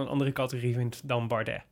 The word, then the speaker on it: Bardet: Bardet.